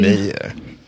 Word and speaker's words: vomir: And it's V-O-M-I-R, which Google pronounces this way.